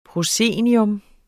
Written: proscenium

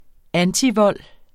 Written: antivold

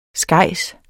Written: skejs

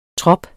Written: trop

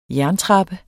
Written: jerntrappe

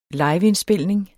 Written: liveindspilning

